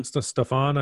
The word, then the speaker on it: Stephon: I mean, he rightfully is pissed because now they got Cam Newton and Stephen Gilmore back. Stephen, right? Stephen Gilmore? Stephen? – Stephon, I think is maybe how you say it.